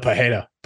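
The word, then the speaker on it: Pereira: You nailed the Pajeda pronunciation a couple times.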